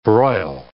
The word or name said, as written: broil